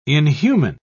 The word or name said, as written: inhuman